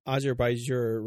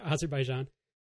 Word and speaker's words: Azerbaijan: We've mentioned a few times that is still on the calendar, and it's Azerbaijan, right? Did I say it right? – No, Azerbaijan.